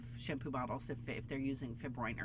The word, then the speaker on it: fibroin: So the protein that we make silk, that silk fibers are made from, nature, silk fibers are made from a protein called fibroin. Such a 20th century word. Fibroin, it rhymes with groin.